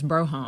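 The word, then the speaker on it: Brougham: I'd love a mid-80s Fleetwood Brome, but... – I'd want a two-door. – Yeah, so you got to say it right, too. It's Broham.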